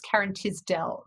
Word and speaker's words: Karen Tisdell: So it's Karen Tisdell. So it's TISDELL, Karen Tisdell.